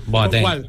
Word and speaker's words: Boateng: Pero explícame cómo se pronuncia entonces. – No, que es Boateng.